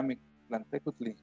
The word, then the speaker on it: Mictlantecuhtli: O la mayoría de palabras en náhuatl son graves. Entonces, la sílaba tónica está en la pero. Okay. Por ejemplo, Mictlantecuhtli sería Mictlantecuhtli.